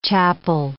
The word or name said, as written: chapel